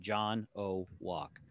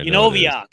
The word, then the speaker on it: Janowiack: In my high school yearbook, my favorite saying was, no, you pronounce it John O. Walk. – John O. Walk. – Yeah, it's pronounced just like it's spelled.